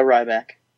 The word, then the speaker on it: Ryback: Did you say Ryback wrong? – Did I? – No, he said it right. – The Ryback.